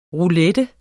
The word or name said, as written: ROULETTEN